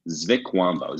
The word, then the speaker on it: Zvikwambo: There is their version of a goblin is called I'm gonna butcher this, a Zvikwambo. – And a Zvikwambo. – Yeah, Zvikwambo. Z-V-I-K-W-A-M-B-O. Zvikwambo.